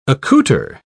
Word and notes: accouter: British English

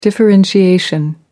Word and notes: differentiation: American English

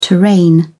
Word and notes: terrain: British English

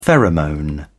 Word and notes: pheromone: British English

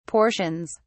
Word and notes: portions: British English

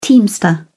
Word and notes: teamster: British English

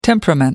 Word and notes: temperament: American English

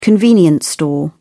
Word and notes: convenience store: British English

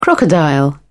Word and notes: crocodile: British English

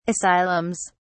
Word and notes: asylums: British English

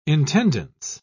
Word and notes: intendance: British English

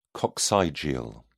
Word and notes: coccygeal: British English

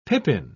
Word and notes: pippin: British English

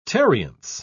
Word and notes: tarriance: British English

